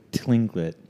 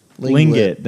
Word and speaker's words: Tlingit: I don't think that's how you pronounce that. – How would you pronounce it? – I think the T might... – T-L-I-N-G-I-T. – It might be silent. – Whatever. Lingit, then.